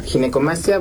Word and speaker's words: Ginecomastia: Pues muchas gracias, y sí, definitivamente creo que es una, para empezar la palabra, le decía, ¿cómo se pronuncia? Ginecomastia. – Sí, exacto, es ginecomastia.